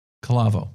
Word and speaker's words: Calavo: When you wanted to talk about Calavo, how do you pronounce it? Calavo. – Calavo.